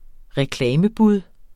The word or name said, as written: reklamebud